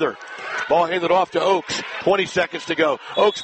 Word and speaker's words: Ochs: 5'5", Sarah Oaks, spelled O-C-H-S, pronounced just like the tree.